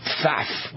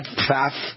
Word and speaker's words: Pfaff: Wisconsin Democrat Brad Pfaff, and it looks like that's how you pronounce his name, P-F-A-F-F, Pfaff, is running, unless it's Pfaff, is running to succeed retiring Representative Ron Kind, isn't getting any outside backup in his race against Republican Derek Van Orden.